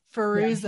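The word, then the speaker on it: Fairuza: Did I say it right? Fazuka? – No. Firuza. Yeah.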